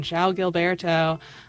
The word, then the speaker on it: João Gilberto: and I just remember looking at his name and being like, oh my god, how do I say that? But now I've got it down, João Gilberto.